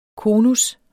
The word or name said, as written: konus